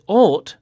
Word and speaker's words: ought: Now, the words thought, spelt T-H-O-U-G-H-T, and ought, spelt O-U-G-H-T, both have the O-U-G-H pattern in them, and it's pronounced all. Thought. Ought. – Thought. Ought. – Hmm. – And there are more O-U-G-H verbs with the same pronunciation, like fought, the past of fight, and bought, the past of buy.